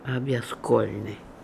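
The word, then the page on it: Obligolnian: Russian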